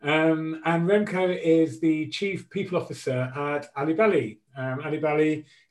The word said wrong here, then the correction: Albelli: if I pronounced it right, I'll let Remco correct me. Albelli, there we go.